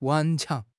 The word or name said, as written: 顽强